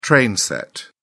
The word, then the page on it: train set: British English